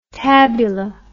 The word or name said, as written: tabula